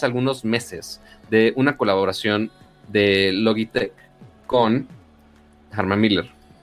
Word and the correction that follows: Herman Miller: que mm. Herman Miller, se pronuncia así Herman Miller.